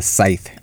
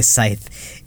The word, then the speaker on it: scythe: The college kids get spooked when Dale approaches them to say hello while he's carrying a scythe. Is that how you pronounce it? – I think it's called a scythe. – Oh, the C is silent? – I think the C is silent, believe.